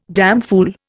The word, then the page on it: damfool: Indian